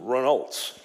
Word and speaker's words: Renault: Now, nobody knows what they are over here because everybody calls them Renaults. But if you're French, then you call them by the proper way of pronunciation, which is Renault.